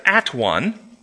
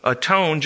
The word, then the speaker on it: atone: And it soon turned into, I'm going to go make them at one. But it was pronounced in the Old English, a tone, just like when you're all by yourself, you're not all one, you're alone. So it's pronounced a tone.